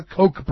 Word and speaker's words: Koch: It makes sense that the Koch brothers, or the Koch brothers. I'm so tired of talking about these two, because I always say their name wrong. It's the Koch brothers, David and Charles, the billionaires.